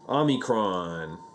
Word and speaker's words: Omicron: There's an Omi variant. That's how you pronounce it, right? – Omicron.